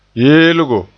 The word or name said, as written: yeelgo